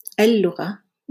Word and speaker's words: اللغة: So uh, if there is no pause before the hamza of al, we don't pronounce that hamza. So we say اللغة, but we say أدرس اللغة.